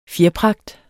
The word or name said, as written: fjerpragt